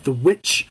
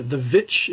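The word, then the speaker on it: The VVitch: It's a follow-up movie from Robert Eggers, who um, came out with The Witch a couple years before, or The Vitch, if you want to do it the way it's spelt.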